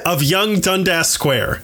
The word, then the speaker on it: Dundas: But no, I looked it up and it is pronounced Dundas.